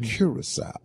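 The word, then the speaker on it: Curaçao: Curacao.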